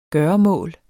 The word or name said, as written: gøremål